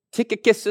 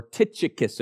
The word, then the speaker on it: Tychicus: Tychicus is one of them. Or Tychicus, if you want to pronounce it wrong. I've pronounced it wrong all my life.